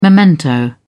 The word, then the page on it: memento: British English